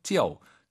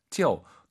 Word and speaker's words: 旧: 아유 사이에 오 넣어서 쩨오 쩨오. 내가 바로 네 엄마다 할때그 바로와 똑같은 발음이죠. 하지만 이건 낡았다예요.